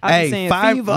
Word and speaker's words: Fiverr: Fiverr.